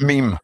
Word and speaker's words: meme: Just before we go any further, you know it's not pronounced Mimi, right? – Yeah, you said Mimi. – Oh. – It's pronounced meme. It's a meme, okay? – I'm so used to saying me.